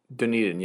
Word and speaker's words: Dunedin: Dunedin, yes. – That's how you say it? – It's okay. A city, yeah. – I've never known if it's Dunedin or Dunedin. – It's like Crocodile Dundee.